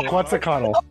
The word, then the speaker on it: Quetzalcoatl: Forgive my pronunciation of the name, but I Quetzalcoatl, I think, is the name of the... – That's very good. – Is that how you say it? – Quetzalcoatl. Yeah. – Quetzalcoatl. Okay. Quetzalcoatl.